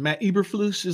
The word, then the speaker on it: Eberflus: Matt Eberflus is what you're talking about.